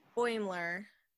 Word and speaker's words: Boimler: Boimler. – Like Boiler with an M.